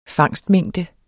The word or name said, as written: fangstmængde